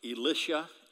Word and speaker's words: Elisha: I'm going to say it the British way. Elisha and Elijah. British say Elisha because the names in, in American English are too close together.